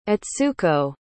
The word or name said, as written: etsuko